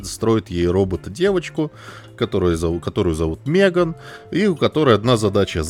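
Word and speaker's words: M3GAN: Значит, история такая, значит, 3 года назад выходил фильм, который называется «Мтриган», который на самом деле, ну, читается как «Меган», но это, блядь, модная еще в 90-х, мне кажется, хуйня цифры вставлять в эти в названия.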